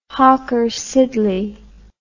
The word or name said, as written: hawker siddeley